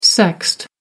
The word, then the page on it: sexed: American English